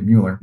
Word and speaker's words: Mueller: I think if you look back at what John, is it Mueller or Miller? I can never pronounce his name correctly. Mueller.